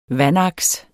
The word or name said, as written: vandaks